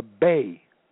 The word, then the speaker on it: Bey: In 1801, which is the first Barbary and European War in America, in 1801, the Bay, B-E-Y, in 1801, the Bay, and it is spelled B-E-Y, of Tripoli, formally declared war against the United States.